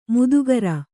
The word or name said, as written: ಮಾಧುಕರಿ